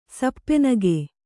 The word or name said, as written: ಸಪ್ಪೆನಗೆ